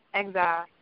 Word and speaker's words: XYLE: They call him Exile, but his name is XYLE. That's how you spell it on all social media.